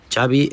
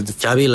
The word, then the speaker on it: Xavi: Xavi Hernández. – Xavi. Okay. – Yeah. So I heard the... Xavi like chocolate.